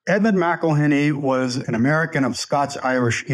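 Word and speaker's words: McIlhenny: And you mentioned this name, Edmund McElhenney. Is that how we pronounce it? – McElhenney.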